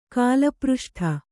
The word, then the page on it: ಕಾಲಪೃಷ್ಠ: kālapřṣṭha